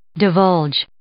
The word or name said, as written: divulge